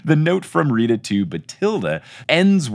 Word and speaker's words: Bathilda: Fun fact about the audiobooks, Stephen Fry pronounces Bathilda Batilda. And is that the real pronunciation or is that a British thing? I keep thinking it was Bathilda. – Well, Jim Dale also pronounces it as Batilda. – Batilda. – Okay. – I always have read it to rhyme with Matilda, which is also spelled with an H. – Oh, Matilda's with an H? Okay. Mm-hmm. Then it probably is Batilda.